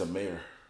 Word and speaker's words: Amare: It's Amir. – Amir? – Yeah. A-M-A-R-E. Amir. – A-M-A-R-E.